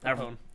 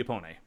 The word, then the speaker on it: Apone: I keep pronouncing it with a fucking accent, and I shouldn't. – No. Like, Gorman, I was wondering, I was like, how is that pronounced? And then Gorman, everybody in the movie calls him Apon. – Apon, so. – Yeah. – Yeah. – Because it looks like it should be Apone, but it's not.